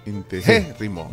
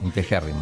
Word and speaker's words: integérrimo: Es Esdrújula. Lleva acento en la, e, en la segunda E. Sí. – Integérrimo. – Integérrimo.